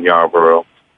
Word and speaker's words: Yarborough: You know, I'm on all the social media I'm on Facebook under my name, Ryan Yarborough. Twitter, I'm at Yarborough16, and that's spelled Y-A-R, B as in boy, R-O, and the number 16.